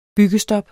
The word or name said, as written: byggestop